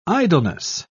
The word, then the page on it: idleness: British English